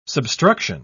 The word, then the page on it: substruction: British English